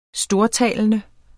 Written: STORTALENDE

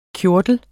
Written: KJORTLERNES

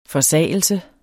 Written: forsagelse

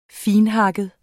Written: finthakket

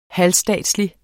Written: halvstatslig

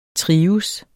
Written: trives

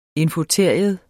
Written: infoteria

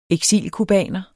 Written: eksilcubaner

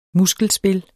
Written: muskelspil

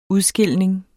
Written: udskilning

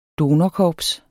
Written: donorkorps

